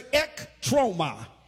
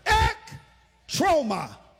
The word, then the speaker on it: ektroma: The word is ektroma. Let the whole church say ektroma. Look at your neighbor and tell them, you just learned Greek. Go ahead on with your bad self. Ektroma. It is a compound word. It is spelled for the note taker, E-K-T-R-O-M-A.